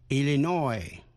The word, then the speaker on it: Illinois: Illinois. Illinois. Nhấn mạnh vần cuối.